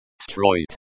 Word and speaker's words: строить: Строить. The pronunciation is similar to destroy it.